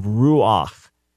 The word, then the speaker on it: ruach: originally would have been something like ruchu. Once you get rid of the u at the end, you have ruch, which is considered hard to pronounce. So you end up with this non-syllabic glide vowel. So you have ruach, or the word for tablet, luach, okay?